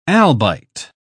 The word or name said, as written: albite